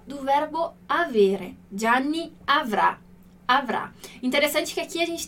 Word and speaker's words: avrà: Como o futuro, como a gente está vendo aqui, a nossa sílaba tônica, então onde cai a intensidade de quando a gente fala a palavra, é na última letra, na última sílaba, né?